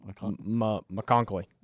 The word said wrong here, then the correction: McConkey: McConkley? I don't think, no, it's just McConkey. – McConkey, I think, yeah. See, we were butchering this man's name.